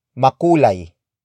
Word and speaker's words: makulay: For example, makulay ang syudad. Makulay ang syudad. Makulay ang syudad. It means the city is colorful. This sentence is in PF format. Word for word it's makulay ang syudad.